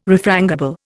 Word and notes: refrangible: Indian

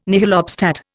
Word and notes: nihil obstat: Indian

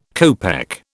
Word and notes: copeck: British English

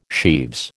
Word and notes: sheaves: American English

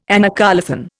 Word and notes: anacoluthon: Indian

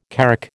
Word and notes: carrack: American English

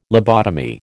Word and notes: lobotomy: American English

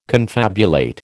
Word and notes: confabulate: American English

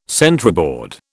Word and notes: centreboard: British English

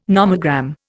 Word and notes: nomogram: Indian

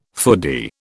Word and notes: foodie: British English

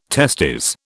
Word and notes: testes: British English

